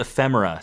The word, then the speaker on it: ephemera: It's the, they call it the collectible ephemera. Am I saying that correctly? We practiced this. – We did, yes. – Yeah, the collectible ephemera section.